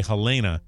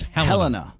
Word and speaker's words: Helena: A lot of people say Helena, but it's Helena.